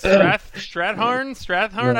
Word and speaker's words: Strathairn: how do you say his last name? I don't know. – That's right. Strathairn. Yeah. I always mess that up.